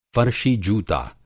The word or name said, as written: फ़र्शी-जूता